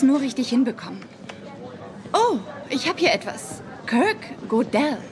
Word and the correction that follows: Gödel: Gödel. Das wird Gödel ausgesprochen, weil da diese frechen Punkte auf dem O sind.